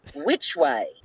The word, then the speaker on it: WVCHWY: It's the name of the act is, now I have to spell it out, it's WVCHWY, but that Zavichery. Is pronounced Which Way.